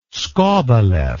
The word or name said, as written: skobelev